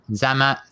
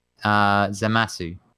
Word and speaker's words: Zamasu: Zama, thank you. Automatok, thank you. Automatok is in the chat. Zamasu.